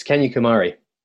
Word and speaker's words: Kanyakumari: Uh, it's Kanyakumari.